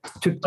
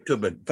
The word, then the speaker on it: Thubten: 6 or 7 books at the moment, but this one's called A Monk's Guide to Happiness by a guy called Gilong Thubten. I don't know if I pronounced that anywhere near. – It's just a Thubten. – Okay, Thubten, thank you.